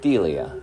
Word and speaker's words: Delia: It's D-E-L-I-A. – How do you say that? – Delia. – Delia.